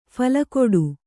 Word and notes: ಫಲಕೊಡು: phala koḍu

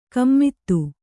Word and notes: ಗುಮ್ಮತಿ: Kannada

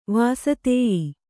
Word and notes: ವಾಸತೇಯಿ: vāsatēyi

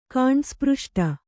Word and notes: ಕಾಂಡಸ್ಪøಷ್ಟ: Kannada

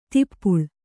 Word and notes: ದೀಪಾಳಿ: kannada

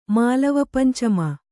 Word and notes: ಮಾಳವಪಂಚಮ: māḷava pancama

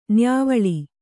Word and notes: ನ್ಯಾವಳ: nyāvaḷa

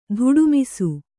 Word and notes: ಧುಡುಮಿಸು: Kannada

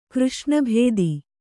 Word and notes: ಕೃಷ್ಣಭೇದಿ: křṣṇa bhēdi

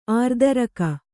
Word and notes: ಆರ್ದರಕ: ārdaraka

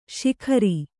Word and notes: ಶಿಖರಿ: Kannada

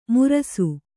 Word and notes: ಮುರಸು: murasu; Kannada

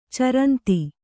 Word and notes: ಚರಂತ: caranta

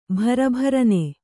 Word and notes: ಬಿರಬಿರನೆ: Kannada

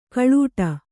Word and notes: ಕಲ್ಲೆಡೆ: kalleḍe

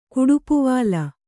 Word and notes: ಕುಡುಪುವಾಲ: Kannada

